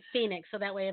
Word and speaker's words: Fenix: And this is F-E-N-I-X LD20. – Fini.